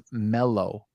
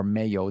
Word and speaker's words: mello: The word is mello or meo, depending how you want to pronounce it, but it's M-E-L-L-O in its regular tense.